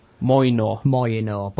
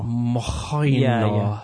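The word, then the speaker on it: Mjölnir: Moynor. But everyone says it differently. That's how I say it. – It's got a J in it. – Yeah, it's Moynor. Moynor. – Moynor.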